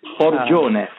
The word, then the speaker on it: Forgione: He was born Francesco, and forgive me if I pronounce the name, Fiorgina? – Forgione, almost close.